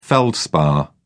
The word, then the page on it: feldspar: British English